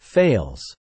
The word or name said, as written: fails